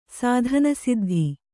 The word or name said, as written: ಸಾಧನಸಿದ್ಧಿ